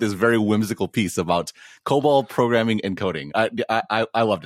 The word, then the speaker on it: COBOL: Is it COBOL? – Yeah, COBOL.